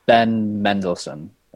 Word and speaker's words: Mendelsohn: It's apparently going to have Sam Jackson and Ben Mandelson. I don't really know how to pronounce his name. – Ben Mendelsohn, I believe. – Mendelsohn. Mm-hmm. Yeah, that looks right.